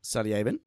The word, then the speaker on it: Salyavin: So Chronotis, yeah, um, slash uh, master criminal whose name I can't remember how to pronounce, Sally Sally Salyavin.